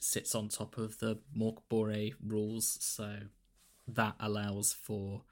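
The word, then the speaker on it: Mörk Borg: So I looked at Mokbore, is how you're supposed to pronounce it, and Pirates Bore came out while we were developing our ideas, which is a pirate-based version of that.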